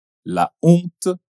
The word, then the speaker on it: la honte: Porém, palavras comme « la honte ou le hasard » também começa avec la letra H, mas a pronúncia un um peu pouco diferente. Consegue reparar? Les hommes, l'hôpital, la honte, le hasard.